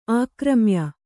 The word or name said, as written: ಆಕ್ರಮ್ಯ